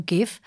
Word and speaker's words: GIF: who insisted at the 2013 Webby Awards show that the format was always intended to be pronounced as GIF, with a soft G, as a nod to the American peanut butter brand, GIF.